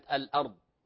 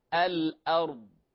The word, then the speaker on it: الأرض: لاحظوا الراء في كلمة الأرض، فقلقلة الراء البعض قد يقرأها الأرض، الأرض، هذا خطأ، فإما أن تقلقل أو تكرر هذا خطأ، الأرض هذا خطأ، فالأصل أن تنطق ساكنة بحركة من غير تكلف، الأرض، الأرض، وهكذا.